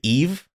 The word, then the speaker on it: Yves: I always say Eve, just like E-V-E, even though it's Y-V-E-S. I think it's Eve.